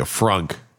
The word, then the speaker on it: frunk: That's not me mispronouncing things because I'm sick right now. It's literally a frunk. It's the front trunk of a Tesla.